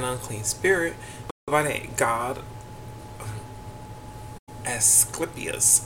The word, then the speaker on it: Asclepius: Okay, it's pronounced as Asclepius,